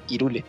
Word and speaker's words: Hyrule: la manera correcta de, para nosotros en esta región es que se pronuncie eh, Hyrule, es Irule.